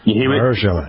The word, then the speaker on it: Mahershala: Mahershala. – Mahershala.